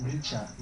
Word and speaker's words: Nietzsche: Always pronounce it Nietzsche, not Nietzsche. That sounds like scratching a chalkboard with overgrown nails or something.